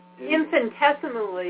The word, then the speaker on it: infinitesimally: infinitesimally. Infinitesimally. How do you pronounce that? – Infinitesimally. – Thank you very much.